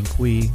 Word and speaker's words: Cui: And of course, you could find me, Brian Kui, my last name is spelled C-U-I, on LinkedIn.